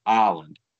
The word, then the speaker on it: island: Well, if it's island, why is there an S and why is it got to be silent?